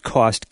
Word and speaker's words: KAUST: I think it's pronounced KAUST, K-A-U-S-T, which is certainly appropriate since it will cost the king